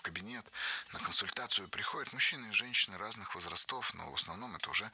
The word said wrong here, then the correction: возрастов: Было сказано «возрастов», но это неверно. Ударение в нашем слове должно падать на первый слог «возрастов».